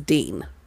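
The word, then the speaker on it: Dene: Like you said, the pronunciation is Dean, right? – Yeah, that's what I saw.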